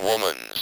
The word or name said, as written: woman's